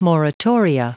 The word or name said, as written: moratoria